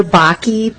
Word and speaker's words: Bakke: My name is Heather Bakke, B-A-K-K-E.